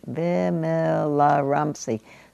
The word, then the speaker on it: Vimalaramsi: Vimalaramsi.